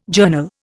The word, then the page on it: journal: Indian